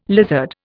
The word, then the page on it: lizard: Indian